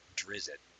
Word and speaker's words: Drizzt: He runs across a human, and he says his name to the human, and the human repeats it back phonetically, and it's Drizzt.